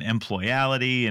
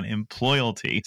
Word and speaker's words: Employalty: Well, first of all, you get bonus points for getting the pronunciation right. Because, you know, I've had a lot of conversations lately where I've gotten employality and employability, but you nailed it, man. Employalty.